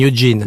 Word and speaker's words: Eugene: E-U-G-E-N-E. Comme Eugène. – Mais pourquoi on prononce Eugène alors? – Ben parce que, effectivement, ça se passe pas chez nous en France.